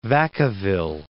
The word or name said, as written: Vacaville